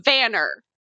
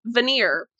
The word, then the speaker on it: Vanir: And I cannot tell you how much I was screaming at the screen when they kept talking about the Vanner, as they couldn't even say the name right.